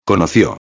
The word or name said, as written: conocio